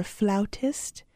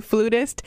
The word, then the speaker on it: flautist: You play the flute, you're a flautist. Remember when I was saying that wrong? – Yeah. – I was calling you a flutist. – I thought it was flutist, too. I didn't know it was flautist.